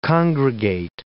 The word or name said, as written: congregate